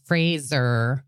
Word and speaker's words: Fraser: Apparently, it's I said his name wrong last time and it's Fraser.